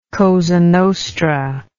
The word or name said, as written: cosa nostra